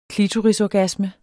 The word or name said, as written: klitorisorgasme